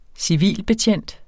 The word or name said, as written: civilbetjent